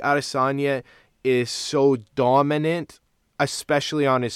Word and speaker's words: Adesanya: How'd you say to pronounce it now? – Arestanya. I hope I'm right on that, but I did hear that from multiple guys in the know.